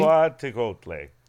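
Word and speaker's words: Cihuateotl: Yes, the singular is Cihuateotle, I believe. – Can I try something? – Sure. You want to repeat after me? – Cihuateotle. – Yes, Cihuateotle. – Cihuateotle. – I think it rhymes with chipotle.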